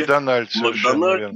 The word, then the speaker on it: Макдональд: и произносим с ударением на последнем слоге. – Макдональд, будущий маршал Франции.